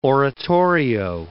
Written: oratorio

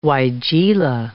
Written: weigela

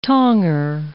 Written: tonger

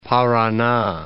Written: Parana

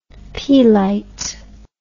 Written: Peelite

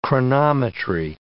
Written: chronometry

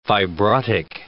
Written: fibrotic

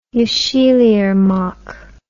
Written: yesilirmak

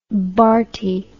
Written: Bharti

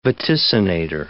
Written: vaticinator

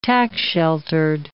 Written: tax-sheltered

